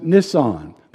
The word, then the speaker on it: Nisan: Now, the day is the 14th day of Nisan, N-I-S-A-N. Now, a lot of people look at that and they pronounce it Nisan. That's not correct. You would be incorrect if you said that. So just understand it's the 14th day of Nisan.